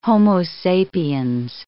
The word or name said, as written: Homo sapiens